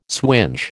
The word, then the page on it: swinge: American English